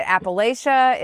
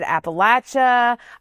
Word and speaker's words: Appalachia: Is it Appalachia? Is it Appalachia? I'm going to tell you right now, I use it interchangeably depending on,